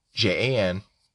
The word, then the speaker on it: Janmark: Janmark, not Yen. Jan, not Janmark. – Janmark. – Janmark. – Janmark. – Janmark. – You said J. – Yeah, but it's pronounced as a Y because he's from some other country.